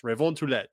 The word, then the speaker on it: Revontulet: They actually posted the pronunciation of this on their Twitter account at mn Revontulet on Twitter. It's Revontulet. It's uh, it's French.